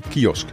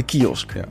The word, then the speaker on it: kiosk: Kiosk. – De kiosk. – Ja, jij komt daar nooit. – Maar je zegt het heel raar. Je zegt kiosk. – Kiosk. – Ja, je zegt de klemtoon verkeerd.